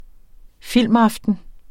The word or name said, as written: filmaften